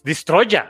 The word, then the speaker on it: Destroyer: Y que mató a Godzilla, es Destroyer, así se pronuncia y así está escrito.